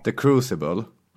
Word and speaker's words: The Crucible: The Crucible.